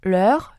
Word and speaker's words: leur: 자, 그들의, 그녀들의 해서, 자, 이게, 으와 을과 만나기 때문에 발음이 정말 어렵습니다. – 맞아요. – 자, 엄청 긴장하고 있어요. 자, 여러분도 긴장 한번 하시고 읽어보도록 할게요. Leur, leur, Leur, leur, leur.